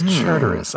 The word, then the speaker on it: Charteris: Leslie Charter. Anybody want to correct my pronunciation of Charteris? Okay, it is Charteris.